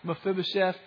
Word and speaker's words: Mephibosheth: You already knew how to pronounce that, Mephibosheth.